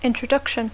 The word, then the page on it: introduction: English